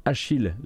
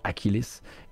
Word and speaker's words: Achilles: Euh, il s'appelle Achille Legends Untold, mais si on voulait vraiment le bien prononcer, il faudrait dire Achilles Legends Untold, une sorte de...